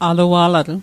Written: alua'latl

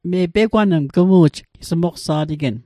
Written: pegwang

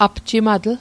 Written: apjimatl

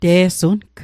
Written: te'sung

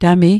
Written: tami

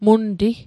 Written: munti

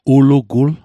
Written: ulugul

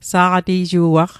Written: sa'qati'ju'aq

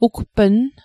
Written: ugpnn

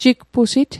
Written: jigpusit

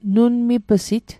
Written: nunmip'sit

